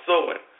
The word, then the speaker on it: Samhain: And the word is, like I said, it's pronounced Samhain.